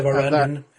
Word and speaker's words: Varangian: and that is our next event in the Vangarian Heresy series. – The Varangian. – Varangian series.